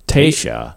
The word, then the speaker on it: Taysha: The origin of Texas's name is from the word Taisha. – Taisha. Is that Latin? – No. It's a Caddo from the Caddo Indian tribe. – Oh, I didn't know that. – Yeah, and it's spelled Taysha.